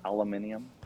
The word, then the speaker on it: aluminium: Does she say it like how the UK people say it, aluminium? – No, she literally goes aluminum foil.